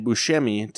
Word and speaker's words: Buscemi: Because I think it's just B-U-S-C. – EMI. – Yeah. – Yeah. – And Buscemi, to make a sh, like the C in Italian is usually a ch noise, mm-hmm. not a k or a s or whatever.